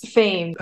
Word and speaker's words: Phanes: is it Phanes? P-H-A-N-E-S? – Yes, Fane.